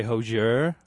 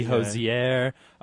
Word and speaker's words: Hozier: So we say Hozier, but it can be Hozier. It's H-O-Z-I-E-R.